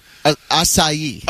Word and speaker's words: acai: I was calling it Asia. – Acai. – Oh, yeah, okay. – Yeah, everybody in L.A. knows how to pronounce acai. – Yeah, in Cleveland, not so much. – A-C-A-I.